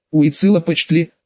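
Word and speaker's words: Уицилопочтли: Кстати, сестрица, обратилась Марфочка Карара, я поняла, что за корона, но что еще за Уицила Почтли? Уицила Почтли, поправила Шторм, умудрившись с первой попытки выговорить правильно.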